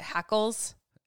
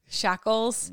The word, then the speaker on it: hackles: Immediately, hackles? Am I saying it right? Shackles? – No, not shackles. – What is it? You're right the first time.